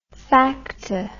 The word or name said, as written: facta